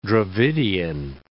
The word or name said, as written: Dravidian